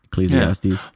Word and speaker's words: Ecclesiastes: I gotta I gotta keep saying Ecclesiastics. You know? Ecclesiastes? Please. Did I say it right or wrong? I don't know. Uh, I'm not even sure if ah. I'm saying it right. Ecclesiastes. – Yeah.